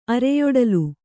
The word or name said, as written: ಅರೆಯೊಡಲು